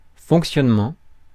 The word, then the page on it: fonctionnement: France; [fɔ̃k.sjɔn.mɑ̃]